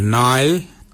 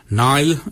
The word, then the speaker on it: ngarly: Warnong is spelled W-O-N-D-I-L, warnong. Your nail is your underarm. Nail is spelled N-G-A-R-L-Y.